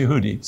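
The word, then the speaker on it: Yehudi (יהודי): In Hebrew, Judah is Yehuda and Jew is Yehudi.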